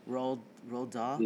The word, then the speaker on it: Roald Dahl: Roald Dahl? – Yeah.